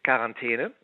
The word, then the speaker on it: Quarantäne: Quarantäne. – Nur mit K, Quarantäne. Ja. Quarantäne. Also ein bisschen eingedeutscht ist es natürlich schon. Hinten sagen wir eine und nicht ein.